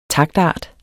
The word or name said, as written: TAKTART